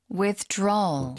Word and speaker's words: withdrawal: Withdrawal. – 뒤쪽에 힘을 주고 좀 끌어주세요.